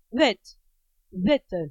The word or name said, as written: wit